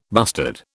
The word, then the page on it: bustard: British English